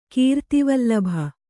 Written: ಕೀರ್ತಿವಲ್ಲಭ

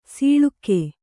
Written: ಸೊಳಗ

